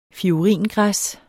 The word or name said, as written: fioringræs